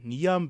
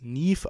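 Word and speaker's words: Niamh: Denn wie wahrscheinlich auch schon im Folgentitel, steht ja der Name Niam, beziehungsweise wird es Nief ausgesprochen.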